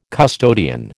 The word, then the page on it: custodian: American English